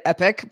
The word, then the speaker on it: EPYC: So my, first of all, I called it EPYC because I've never heard EPIC pronounced out loud before, which makes a lot more sense.